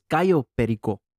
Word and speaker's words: Cayo Perico: You said Cayo Perico. Like a white person. It's Cayo Perico.